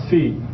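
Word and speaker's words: C (Ξ): Now, how do you pronounce that? XI. Actually, it's pronounced C, and I'll write it down for you. It's however you would pronounce this word, that's how it's pronounced. C.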